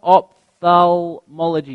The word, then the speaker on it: ophthalmology: Ophthalmology, I think, am I pronouncing it right? Ophthalmology, yes. Ophthalmology, an ophthalmologist is an eye doctor, I take it.